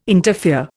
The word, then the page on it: interfere: Indian